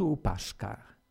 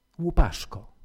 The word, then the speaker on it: Łupaszko: Łupaszko czy Łupaszka? Pytają czasami ludzie. Łupaszko. W mowie kresowej O nieakcentowane zamienia się na A. Trochę pod wpływem języka rosyjskiego. Na kresach ludzie tak mówią Łupaszka, Łupaszka, ale właściwie Łupaszko.